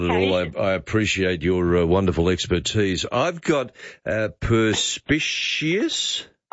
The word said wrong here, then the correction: perspicacious: No, perspicacious.